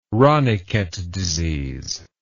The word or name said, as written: ranikhet disease